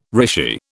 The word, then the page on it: rishi: British English